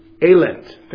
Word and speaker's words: Elend: But the word translated in the original for misery from the German, and I won't pronounce this correctly, so maybe somebody can help me here, but it's Elend. It's E-L-E-N-D. Elend. Elend. Thank you. Elend.